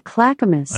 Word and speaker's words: Clackamas: Alexa, how do you say Clackamas? – She just told you. – I pronounce that Clackamas.